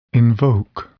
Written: invoke

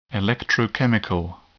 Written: electrochemical

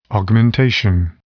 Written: augmentation

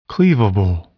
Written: cleavable

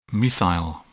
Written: methyl

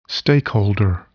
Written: stakeholder

